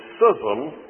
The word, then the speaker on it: schism: But when an individual says that Christ must die, one of the frequent criticisms that is made is that this assumes a schism, yes, that's the way you pronounce it, a schism in the Trinity.